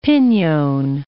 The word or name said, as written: pinyon